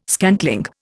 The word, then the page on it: scantling: Indian